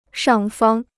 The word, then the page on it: 上方: shàng fāng